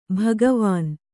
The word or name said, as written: ಭಗವಾನ್